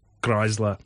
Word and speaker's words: Chrysler: Ĉu vi diras Chrysler? Ĉar mi, pardonu min, mi prononcas kiel francino. – Oh, ne, la usona metodo estas Chrysler, mi pensas.